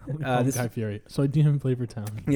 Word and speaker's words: Fieri: So it's Guy Fieri, but I always say Fieri.